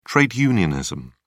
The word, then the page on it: trade unionism: British English